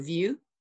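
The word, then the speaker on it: Vör: So Vu. – Okay. – V. Well, it's V. Her name is spelled V-O-R. But you're supposed to pronounce the O like E-U.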